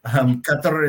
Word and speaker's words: Qatar: we sort of all pronounce the country wrong. Qatar is technically the way to say it correctly in Arabic. And I learned that the sounds involved are not sounds that our Western languages tend to have.